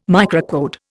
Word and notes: microcode: Indian

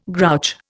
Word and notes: grouch: Indian

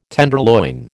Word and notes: tenderloin: American English

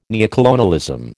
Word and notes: neocolonialism: American English

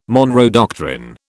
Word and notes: Monroe doctrine: British English

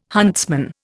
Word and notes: huntsman: Indian

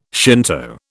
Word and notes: Shinto: British English

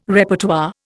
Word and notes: Repertoire: Indian

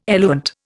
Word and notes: eluent: Indian

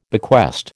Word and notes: bequest: American English

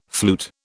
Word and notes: flute: British English

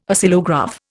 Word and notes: Oscillograph: Indian

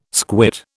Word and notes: squit: British English

